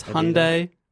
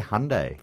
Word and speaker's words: Hyundai: Hyundai. – They say Hyundai. – For Hyundai.